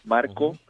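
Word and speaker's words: Marko: Por ejemplo, en mi página personal, mi nombre se escribe Marco con K, Marco Sirias.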